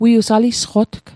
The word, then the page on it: alisqotg: Mi'gmaq